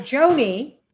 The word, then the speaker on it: Jonie: So you can find me at Leonarda Jonie, J-O-N-I-E.